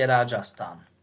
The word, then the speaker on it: Rajasthan: V severo-západnej Indii, v štáte Rajasthan. Tak sa vyslovuje.